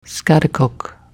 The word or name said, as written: schaghticoke